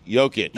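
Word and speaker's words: Jokić: But how do you pronounce the J in his name? – Jovic. – Jovic. But for Jokic, it's... – It's Jokic. – Jokic. – It's all, they're both Yo.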